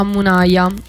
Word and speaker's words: Munaya: E passiamo a Munaya, Il Tam. – Esatto. – Mi scuso tantissimo se ho sbagliato la pronuncia. – No, era perfetto.